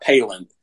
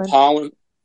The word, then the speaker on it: Pollan: I'm not sure how you say it. It's not Palin, but it's Michael Pollan.